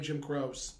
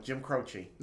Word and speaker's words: Jim Croce: I always pronounced his name Jim Croce. – No, Jim Croce.